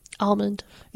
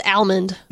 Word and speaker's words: almond: I think we've talked about this, but how do you say the word A-L-M-O-N-D? – Almond. – Yeah, so do I. – Who says almond? – No, it's not almond. It's almond.